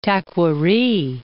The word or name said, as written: Taquari